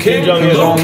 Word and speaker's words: Kim Jong-un: Kim Jong-un.